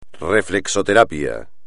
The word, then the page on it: reflexoterapia: espagnol